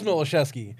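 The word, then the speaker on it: Miłoszewski: Well, it is Milosevsky. – Okay. – But Walt and everyone else on Tell Him Steve Dave calls it Milosevsky, so...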